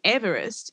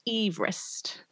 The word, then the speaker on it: Everest: Despite Sir George himself opposing the adoption of his name, it is interesting also to note that the common pronunciation Everest is not even how he pronounced his own surname, which sounded more like Everest.